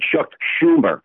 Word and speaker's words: Schumer: It's shoe, like the shoe you put on your feet. It's Chuck Schumer. I know the word, the spelling looks kind of funny, but that's the way his name is pronounced.